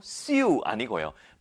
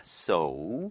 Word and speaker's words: sew: 새우 아니고요. sew 아니고요. 발음은 so입니다. so. ew라서 뭔가 이유 이유 그래서 sew나 뭐 새우 이렇게 읽을 것 같지만 정식 발음은 so입니다. 많이들 실수합니다.